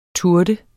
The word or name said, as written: turde